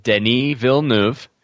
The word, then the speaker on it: Denis Villeneuve: Arrival is the latest film from Canadian filmmaker Denis Villeneuve.